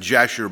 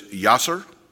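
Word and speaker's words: Yashar: We would pronounce it Jasher, but it's Yasser. The Hebrews would write it with a Y.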